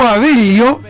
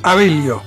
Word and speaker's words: Abello: Gustavo Abello es mi apellido, porque claro, se pronuncia de esta manera. A, B corta, E, doble L, O, Abello. Gustavo Abello Payador, todo junto.